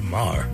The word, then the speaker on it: Maher: I say Maher. – Maher? Yeah, I think you're right, actually.